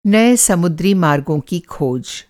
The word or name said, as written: समुद्री